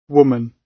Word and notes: woman: British English

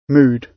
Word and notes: mood: British English

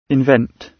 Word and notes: invent: British English

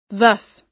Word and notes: thus: American English

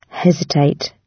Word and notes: hesitate: British English

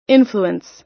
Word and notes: influence: American English

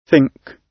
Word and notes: think: British English

